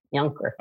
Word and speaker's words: Junker: I'm Christian Napier, and I'm very pleased to be joined today by Joan Junker, or as it's pronounced in Germany, Junker. Is that right? Yes, Junker.